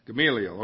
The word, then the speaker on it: Gamaliel: Gamaliel, okay.